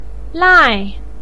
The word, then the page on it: lai4: pinyin